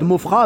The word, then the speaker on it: Maufras: Donc, Romuald Mofra avec un S à la fin qui ne se prononce pas. – Ouais, c'est voilà. Pareil. Ouais, c'est comme ça. – Romuald Mofra, donc M-A-U-F-R-A-S.